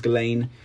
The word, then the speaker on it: Ghislaine: But yeah, proper pronunciation is Ghislaine.